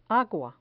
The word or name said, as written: agua